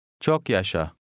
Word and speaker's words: Çok yaşa: The first A has almost an E sound. – Çok yaşa.